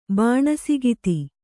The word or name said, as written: ಬಾಣಸಗಿತ್ತಿ